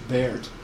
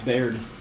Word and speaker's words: Beard: Kevin Beard, B-E-A-R-D. It's common that people mispronounce my last name. It's spelled like Beard, but because of my German ancestry, it's pronounced Baird. And for some reason, my family hung on to that for years and decades and generations. So, Baird, yeah.